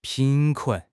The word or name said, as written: 贫困